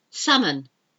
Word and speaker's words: salmon: Salmon. You don't say salmon. It's salmon. S-A-L-M-O-N, and I like salmon.